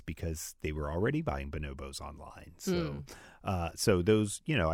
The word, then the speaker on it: Bonobos: Because I wanted to not say it like the monkey, but it is like the monkey. – It Bonobos.